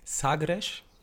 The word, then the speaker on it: Sagres: Sagres, und ja, es wird genauso ausgesprochen, und Superbock.